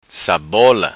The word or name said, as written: Sabola